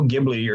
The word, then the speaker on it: Ghibli: Like Studio Ghibli, if you talk to real anime fans, I shouldn't say real anime fans, hardcore anime fans. Studio Ghibli or Ghibli, it's spelled with Ghibli, is one of like the they're like at the top of the list, right?